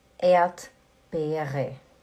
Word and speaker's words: Erdbeere: Você fala Erdbeere. Erdbeere. O, o primeiro R a gente não pronuncia.